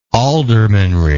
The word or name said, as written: aldermanry